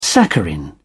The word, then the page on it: saccharin: British English